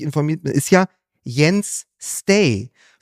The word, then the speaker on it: Stage: Wo ich das ganze Jahr schon abkotze, weil der ja Stage geschrieben wird. – Genau. – Ja. Und ich mich immer wieder überrascht werde, dass sie den Stay aussprechen, aber es ist ja wohl richtig.